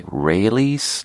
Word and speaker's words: Raley's: It's from a store called Raley's, but it's supposed to be pronounced like Raley's, like Israelis. That's how I try to remember it. Like, it's Israelis. Raley's, but it's spelled R-A-L-E-Y apostrophe S. Raley's.